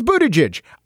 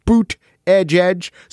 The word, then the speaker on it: Buttigieg: He says Buttigieg. I know phonetically that they tried to help people by making signs and t-shirts that say boot edge edge.